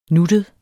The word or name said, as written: nuttet